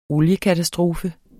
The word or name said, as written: oliekatastrofe